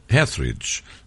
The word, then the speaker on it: Etheridge: uma interpretação fantástica desta Melissa Etheridge, que deve ser conhecida.